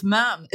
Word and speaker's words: ma'am: You've also got the pronunciation wrong according to the Royal Family official website. They're very strict. – Is it ma'am? – It's ma'am. It's the only bit of protocol they say is a rule.